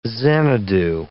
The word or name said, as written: Xanadu